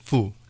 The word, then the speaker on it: Fu: Yes, Moses Fu, F-U.